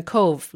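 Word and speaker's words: Cobh: Like, it's an island in a cove, like C-O-V-E, like the English word cove.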